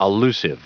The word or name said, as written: allusive